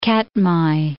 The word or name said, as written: Katmai, Mount